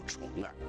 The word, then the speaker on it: 重耳: 有一位叫回望春秋的网友问，重耳这个名字正确的读音应该是什么？他这个名字有什么特殊意思吗？ – 应该读作重耳，这是因为古人的取名啊，常常依据生理特征。那么重耳呢，他的生理也有与众不同之处，就是他啊，偏斜重耳，